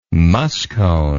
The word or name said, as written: muskone